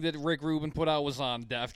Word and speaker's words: Rick Rubin: And then they run into Rick Rubin.